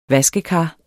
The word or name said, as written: VASKEKAR